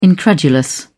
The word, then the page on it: incredulous: British English